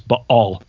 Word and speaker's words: Baal: Or for you scholars out there, just so you know, I know how to say it. It's Baal. There's a glottal stop in there.